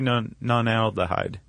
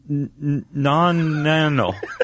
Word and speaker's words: nonanal: Why can't I say that? Non-nanal. – Your eyes just rolled back in your head. – I mean, it's spelled... Well, you can pronounce it however you like, N-O-N-A-N-A-L, but it's an oil that mosquitoes are bonkers for.